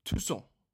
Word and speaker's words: Tussaud: Som jeg har simpelthen vurderet, at det skal udtales Tussaud.